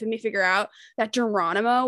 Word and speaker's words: Geronimo: A kid in class told me it's pronounced Geronimo, and I was like, it's not Germono, and he was like, no, and I was like, fuck you, I'm literally nine.